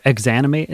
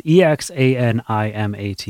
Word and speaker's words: Exanimate: There's a new EP or album, I can't remember, from this local Detroit band called Examinate. They're super heavy and super good. – Cool. I don't know. – Or Exanimate is how you say their name. It's Exanimate. They're super cool. – Exanimate.